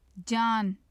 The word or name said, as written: jan